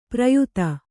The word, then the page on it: ಪ್ರಯಾತ: prayāta